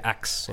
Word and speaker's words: ask: La comunidad negra, por ejemplo, pronuncia ax en lugar de ask.